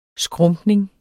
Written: skrumpning

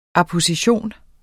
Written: apposition

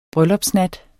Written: bryllupsnat

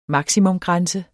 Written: maksimumgrænse

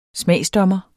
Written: smagsdommer

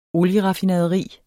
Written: olieraffinaderi

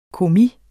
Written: kommis

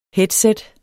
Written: headset

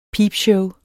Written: peepshow

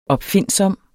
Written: opfindsom

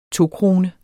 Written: tokrone

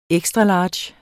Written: XL

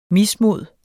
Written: mismod

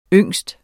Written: ung

